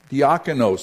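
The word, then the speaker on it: Diakonos (διάκονος): Minister translates the Greek word diakonos, which we saw when we talked about the office of deacon. Diakonos. Sorry, I pronounced it wrong the first time. Diakonos. Forgive me, I thought I had it.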